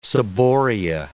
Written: ciboria

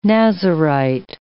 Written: Nazarite

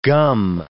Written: gum

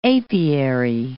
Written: apiary

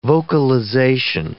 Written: vocalization

